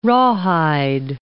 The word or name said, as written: rawhide